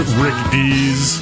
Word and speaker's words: Rick Dees: And you're saying his name wrong. It's Rick Dees.